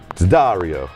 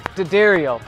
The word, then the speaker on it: D'Addario: And this is the Rig Rundown team, and we're sponsored by D'Addario. – Actually, I think it's pronounced D'Addario. – It's D'Addario, but no matter how you say it, we know it stands for quality.